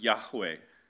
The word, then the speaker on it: Yahweh: This is the covenantal name of the God of Israel, pronounced Yahweh.